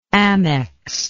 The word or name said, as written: amex